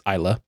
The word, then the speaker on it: Islay: Islay, it's spelled Islay, but it's pronounced Islay.